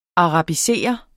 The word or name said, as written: arabiserer